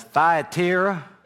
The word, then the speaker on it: Thyatira: How do I pronounce that, Dad? That southern drawl he has. Thyatira.